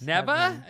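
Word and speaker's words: Nevaeh: N-E-V-A-E-N. – A-E-H. How would you say that? – Neva, I guess. – Neva?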